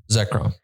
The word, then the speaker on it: Zekrom: Zekrom, yes.